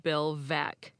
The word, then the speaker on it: Bill Veeck: I told you it was from Baseball Hall of Famer Bill Veeck, but his name is actually pronounced Bill Veeck. The mispronunciation is so common that the title of his autobiography is Veeck as in Wreck.